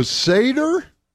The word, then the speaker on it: Seder: It's pronounced S-E-D. I mean, it's spelled S-E-D-E-R, but it is pronounced Seder.